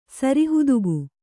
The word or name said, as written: ಸರಿಹುದುಗು